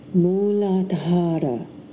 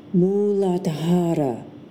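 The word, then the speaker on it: Muladhara (मूलाधार): The first chakra, or chakra, is the root chakra, and that is pronounced Muladhara, Muladhara.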